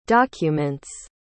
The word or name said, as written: documents